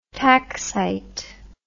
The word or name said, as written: taxite